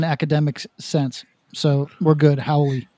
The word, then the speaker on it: Haole: So the term is Haole. – Haole.